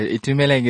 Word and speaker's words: Thumeleng: Yeah, Thumeleng is the obviously the long version, but you know the short version. I'm known as Thumi.